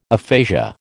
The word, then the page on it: aphasia: American English